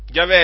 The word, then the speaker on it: Yahweh: Yahweh è la pronuncia ebraica del tetragramma che è il nome di Dio, che è stato tradotto nella Luzzi molte volte con l'Eterno.